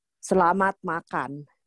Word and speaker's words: Selamat Makan: How did I do? Did I pronounce that all right? – Selamat Makan.